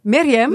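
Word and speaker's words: Myriam (مريم): Est-ce que je la prononce bien? Myriam, c'est ça? – En arabe, c'est Myriam. – Myriam. – Myriam. C'est correct.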